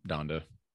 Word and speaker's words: Donda: Yeah, as, as Don, of right now, Donda? Sunday. is that how you say it? Donda. – Yeah, Donda. Donda.